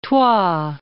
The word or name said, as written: Troyes